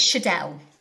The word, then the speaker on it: Schaedel: Yeah, it's Schaedel.